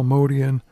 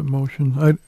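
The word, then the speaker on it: Motian: I heard Bill Evans call him Paul Modian. Usually I've heard people pronounce it Motion. It's interesting because he played with him. He probably would know something.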